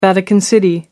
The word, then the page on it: vatican city: American English